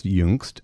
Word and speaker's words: Jungst: So my name is pronounced Jungst, as you said earlier, and it is a German word, but my background is actually a little more diverse.